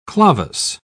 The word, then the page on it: clavus: British English